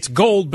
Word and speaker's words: GGOOLLDD: is called Gold, but it's spelled G-G-O-O-L-L-D-D. So it's Gold, but they doubled each letter.